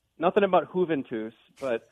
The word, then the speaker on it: Juventus: It's Juventus.